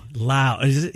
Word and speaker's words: Lowe: Lowe? Who's this dude? – That's not how you say his name either. – Is it Lau? – Lau. Somebody's was weird. I mean, it's Lowe. You would think it's Lowe all day long.